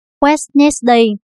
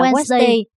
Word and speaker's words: Wednesday: Nếu chúng ta phát âm từ này theo đúng cách đánh vần của nó, thì chúng ta phải nói Wednesday. Nhưng thực tế, chúng ta lại phát âm là Wednesday mà không đếm xỉa gì đến chữ đi.